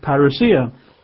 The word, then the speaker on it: parousia: And the word here, coming, is again the Greek word parousia. We would spell that in English as a P for Peter, P-A-R-O-U-S-I-A, parousia, P-A-R-O-U-S-I-A.